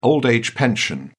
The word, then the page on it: old-age pension: British English